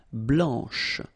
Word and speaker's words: blanche: Okay, so here, masculine, blanc, don't pronounce the final C, and then blanche. Okay, remember when you combine this CH, you get the sound sh, blanche.